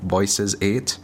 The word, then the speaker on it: VOCES8: And the other one is by Voices 8, but it's spelled V-O-C-E-S 8.